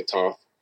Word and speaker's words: Thoth: And so, if you look at Africa, you have Thoth, T H O T H. Some people say Toth, depending on how you want to pronounce it.